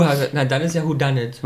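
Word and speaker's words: Whodunit: Aber wie spricht man es richtig aus? – Who done it?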